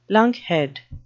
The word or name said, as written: lunkhead